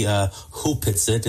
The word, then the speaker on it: Opetsit: It's written Opetsit, but it's actually pronounced properly Hupetsit.